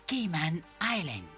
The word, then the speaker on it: Cayman Island: Peut-être oui, eh bien oui, on va parler des îles Caïmans, îles Caïmans, ou en anglais ça se prononce Cayman Island.